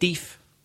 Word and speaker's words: deaf: For example, in Belfast, you could hear, oh, could you speak up, please? I'm a bit deef. People say that. So we understand in our pronunciation, colloquial pronunciation. – I think that's also how they pronounce it in Scotland.